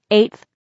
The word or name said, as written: 8th